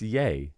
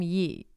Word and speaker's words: Ye: It's yay. – Okay. Because I've heard people call him yee. And I was like, I don't think that's right. – Yee? – Yeah. – I think you're mispronouncing it as yay. – No, I'm saying I've heard people call him that now because you know he changed it. It's Y-E.